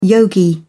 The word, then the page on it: yogi: British English